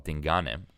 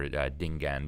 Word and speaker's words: Dingane: And there was a Zulu king called Dingane, or Dingane, depending on what pronunciation you use.